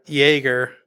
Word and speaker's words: Jaeger: It's the German pronunciation, or Jaeger, means hunter in German.